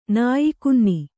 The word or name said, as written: ನಾಯಿಕುನ್ನಿ